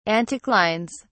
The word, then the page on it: anticlines: British English